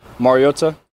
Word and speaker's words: Mariota: Mariota.